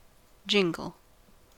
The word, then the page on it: jingle: American English